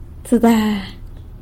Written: tsëda

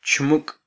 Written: chmuk

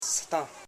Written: si.tan

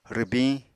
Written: rwbiñ

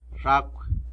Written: zhacw

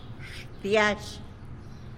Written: xtiazh

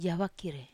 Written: avakir

